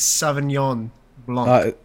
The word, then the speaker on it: Sauvignon Blanc: I need you to try and pronounce that name again. – Sauvignon. Do you know how to say it? – You're really close. – How do you say it? – It's Sauvignon Blanc.